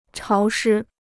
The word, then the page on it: 潮湿: cháo shī